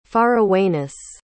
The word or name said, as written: farawayness